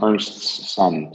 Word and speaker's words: Ernstsson: my dad's name is Ernst, so I'm Ernst's son. So Ernstsson.